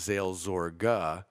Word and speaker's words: Seelsorge: Seel, but the Germans say Seel, not Zeal. Seelsorge.